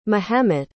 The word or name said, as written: mehemet